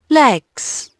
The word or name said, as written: legs